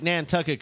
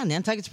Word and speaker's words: Nantucket: I like Nantucket because it rhymes with a curse.